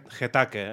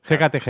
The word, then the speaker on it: Hecate: La T i la K les intercanvio. – Sí, eh? – Em surt Hetake en lloc de Hecate Het.